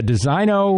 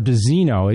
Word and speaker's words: Designo: The uh, Designo or Desino, it can be pronounced either way. It's spelled D E S I G N O. I always pronounce it Designo, but I've heard the Mercedes people call it Desino uh,